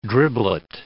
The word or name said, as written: driblet